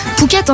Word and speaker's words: Phuket: Phuket, hein, c'est bien comme ça qu'on prononce.